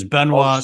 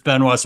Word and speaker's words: Benoit: They wouldn't know how to pronounce my last name because my last name is Benoit, spelled and pronounced just the same as Chris Benoit.